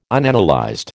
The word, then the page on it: Unanalyzed: American English